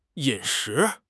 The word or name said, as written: 饮食